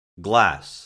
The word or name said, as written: glass